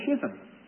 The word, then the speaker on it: schism: that there should be no schism. Now, that's the way you pronounce that word, by the way, if you don't recognize the Queen's English.